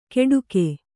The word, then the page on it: ಕೆಡುಕೆ: keḍuke